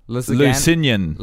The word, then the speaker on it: Lusignan: Lusignan. – Lusignan.